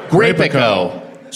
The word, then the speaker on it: Grapico: correct me on the pronunciation. Is it Grapico or Grapico? Grapico. So we got some Grapico.